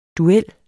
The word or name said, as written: DUELLERNES